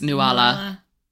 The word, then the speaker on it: Nuala: Before this, right, I call it Nuala and, oh, yeah, and Ceridwen. However, it is pronounced Nuala. And Ceridwen. – Ceridwen, which in, you found out from the audiobook.